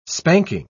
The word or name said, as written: spanking